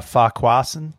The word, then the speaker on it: Farquharson: She's Zimoli Farquharson. You know how to say that last name. What is Farquharson. – It? Farquharson. Yeah. Yeah. Pick 8. – You're putting the emphasis on the wrong syllable there, Sean.